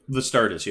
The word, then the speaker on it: Vastardis: Particularly, you pointed out what Vastardis did on that, the one snap. Did I pronounce his name correctly? – Vastardis, yeah. – Yeah. – With a V.